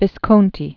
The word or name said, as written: Visconti